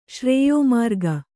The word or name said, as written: ಶ್ರೇಯೋಮಾರ್ಗ